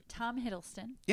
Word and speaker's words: Tom Hiddleston: I have, okay, I always say his name wrong. Go ahead. Tom Hiddleston. – Yeah, you said it right.